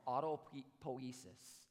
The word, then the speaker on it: autopoiesis: It's, and forgive me if I mispronounce this, science nerds, but it's called autopoiesis.